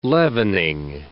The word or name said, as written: leavening